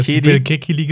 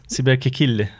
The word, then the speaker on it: Sibel Kekili: Sibel Kekili heißt das. Kekili. Kekili. Also, wenn du es türkisch richtig aussprichst, kannst du ja Sibel, äh, Sibel Kekili.